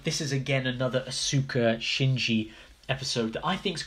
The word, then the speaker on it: Asuka: I'm going to try and use the Japanese pronunciations. Asuka.